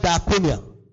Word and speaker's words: diakonia: D-I-D-O-M-I. The word given. When it says ministry or the ministry, it uses the word diakonia. Diakonia in the Greek for ministry. It's spelled D-I-A-K-O-N-I-A. D-I-A-K-O-N-I-A.